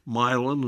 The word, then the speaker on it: Milan: And all through Illinois, we mispronounce names. Milan instead of Milan, Rio instead of Rio. – Right. – We accent the first syllable.